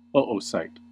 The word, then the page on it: oocyte: American English